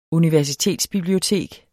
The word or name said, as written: universitetsbibliotek